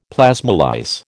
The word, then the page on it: plasmolyse: American English